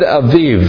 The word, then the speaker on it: Abib: It's spelled A B I B, but it's pronounced Aviv.